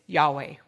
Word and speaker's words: Yahweh: The Jews added vowels to pronounce it Yahweh, which means Lord, Master.